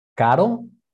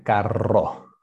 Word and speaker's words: caro: Okay, pronunciación, caro. – Caro. – En carro. So okay. Caro is expensive, carro is car. So caro.